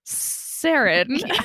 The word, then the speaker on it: sarin: How do you pronounce sarin? – Yeah. – And it said like sarin.